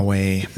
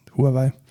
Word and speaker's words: Huawei: Huawei ist die native, also die originale Aussprache. Ja, aber ich glaube, in Europa sagt keiner Huawei, sondern alle nur Huawei.